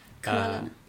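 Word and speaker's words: Hvala, ne: Eh, hon vann nämligen den slovenska eh, finalen då med låten Valane, om man uttalar rätt. – Kvalane. – Kvalane.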